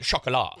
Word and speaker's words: Chocolat: I only remember because I, we were making fun of it because you had to pronounce it chocolat. – Yeah. You know, chocolat. chocolate in French.